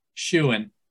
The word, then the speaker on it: Shewen: The name is spelled S-H-E-W-E-N, Schuin.